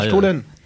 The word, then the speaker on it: Stollen: Nos voisins allemands, le Stollen. ça se prononce comme ça. J'ai pas fait allemand. – Stollen.